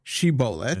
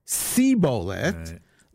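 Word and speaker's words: shibolet (שיבולת): Say this word, shibolet. – And say how you pronounce it. – And if he said sibolet, the lo yachin lidaber kein, they were not able to pronounce it correctly.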